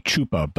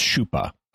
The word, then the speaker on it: chupa: In Spanish, it would be chupa, but in Portuguese, it's chupa, and it means sucker.